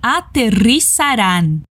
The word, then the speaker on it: aterrizarán: O R vibrado em ri, o Z não faz zumbido como em português. Sa e o A bem aberto no final. An. Repete. Aterrizarán.